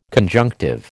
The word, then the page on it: conjunctive: American English